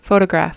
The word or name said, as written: photograph